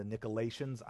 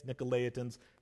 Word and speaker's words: Nicolaitans: I will probably call them the Nicolaitans. I've said that my whole life. That's not how you say it. It's Nicolaitans.